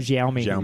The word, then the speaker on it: Xiaomi: And it's from Xiaomi. How do I say it? – Xiaomi.